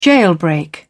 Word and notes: jailbreak: British English

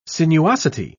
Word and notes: sinuosity: British English

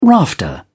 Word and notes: rafter: British English

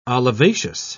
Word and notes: olivaceous: British English

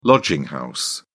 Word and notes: lodging house: British English

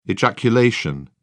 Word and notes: ejaculation: British English

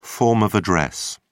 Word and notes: form of address: British English

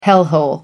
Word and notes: hellhole: American English

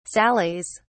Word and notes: sallies: British English

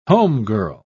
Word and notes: homegirl: British English

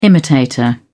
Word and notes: imitator: British English

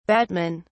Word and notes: batmen: British English

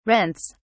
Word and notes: rents: British English

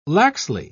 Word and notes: laxly: British English

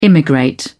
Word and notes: immigrate: British English

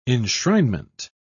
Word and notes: enshrinement: British English

